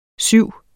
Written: syv

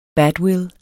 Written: badwill

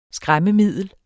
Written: skræmmemiddel

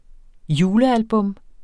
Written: julealbum

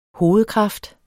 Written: hovedkraft

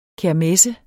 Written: KERMESSENS

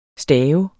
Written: staver

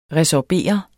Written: resorbere

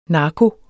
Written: narko

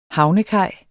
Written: HAVNEKAJ

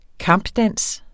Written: kampdans

